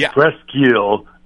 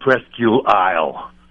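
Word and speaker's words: Presque Isle: It's Presqu'ile, not Presqu'ile Isle. – Jesus. – It's just Presqu'ile. – Okay, thank you. – Presqu'ile. I know it's spelt Isle, but it's eel.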